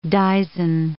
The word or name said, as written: dizen